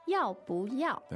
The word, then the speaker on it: 要不要: 要不要? – There you go.